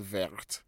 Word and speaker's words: verte: When you add an E to vert, what does it give you? What does it give you? What sound? What pronunciation? You get vert. Vert. V-E-R-T-E. Vert.